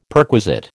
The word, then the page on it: perquisite: American English